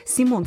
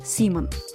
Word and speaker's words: Симон: Сразу стоит отметить, нам придется сталкиваться с тем, что некоторые люди называют этого публициста Симон Соловейчик. Но мы с вами последуем примеру его сына, директора издательского дома 1 сентября Артема Соловейчика, который, говоря об отце, произносит его имя так – Симон.